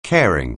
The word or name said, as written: caring